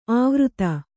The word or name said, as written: ಆವೃತ